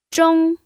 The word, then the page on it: zhong: 1